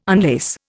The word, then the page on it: unlace: Indian